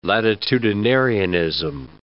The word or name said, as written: latitudinarianism